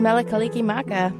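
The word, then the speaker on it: Mele Kalikimaka: Well, that was Melakaliki Maka.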